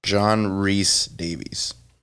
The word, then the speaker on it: John Rhys Davies: It is to rhyme with peace, John Rhys Davies.